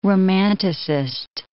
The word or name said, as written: romanticist